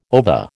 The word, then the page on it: ova: American English